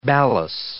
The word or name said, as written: balas